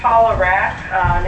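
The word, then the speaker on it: Paula Rak: Paula Rack, name is spelled R-A-K, and I'm representing myself.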